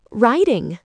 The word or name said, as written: writing